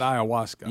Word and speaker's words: ayahuasca: I believe you pronounce it ayahuasca. – Yeah, that's right.